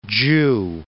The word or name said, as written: Zhu